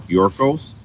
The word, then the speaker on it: Gyorkos: My name's Derrick Gyorkos, and that's spelled G-Y.